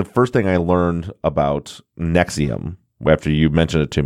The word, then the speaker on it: NXIVM: Is that it's pronounced Nexium, because you sent me, let's talk about NXIVM.